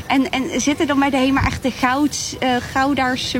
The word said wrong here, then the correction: Goudse: mensen, zeg ik dat goed? – Oh ja, Goudse mensen.